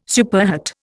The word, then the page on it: superhet: Indian